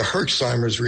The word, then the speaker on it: Herxheimer's: like a Herxheimer's reaction.